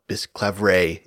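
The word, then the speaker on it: Bisclavret: Our amplifier in this case is Marie de France, whom we heard from way back in episode 18 on The Lay of Bisclavaret, or Bisclavaret if you opt for modern pronunciation.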